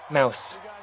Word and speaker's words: Deadmau5: Mouse. But it's spelled with Mouse, a, it's spelled with a 5 instead of an S.